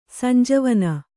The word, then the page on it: ಸಂಜೀವನೆ: Kannada